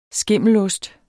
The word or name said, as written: skimmelost